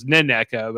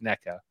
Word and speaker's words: NECA: Like between my two picks, I feel like I'm feeling better about NECA, who I thought was Neneca, but it turns out I think it's just NECA.